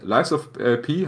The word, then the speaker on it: Lies of P: Ich meine, Lies of P haben wir schon bei unserer Gamescom, unserem Gamescom-Bericht drüber gesprochen.